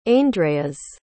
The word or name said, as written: aindreas